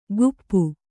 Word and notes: ಕುಪ್ಪು: kuppu; Kannada

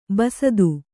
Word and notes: ಬಸ್ತ: Kannada

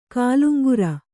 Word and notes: ಕಾಲುಂಗರ: kāluŋgara

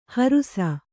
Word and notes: ಹರುಸ: kannada

